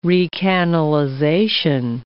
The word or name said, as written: recanalization